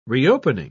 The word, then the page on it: reopening: British English